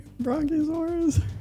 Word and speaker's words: Brachiosaurus: Brachiosaurus? – Yep.